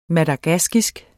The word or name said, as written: madagaskisk